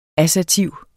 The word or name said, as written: assertiv